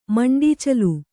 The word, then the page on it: ಮುಂಡೀಚಲು: muṇḍīcalu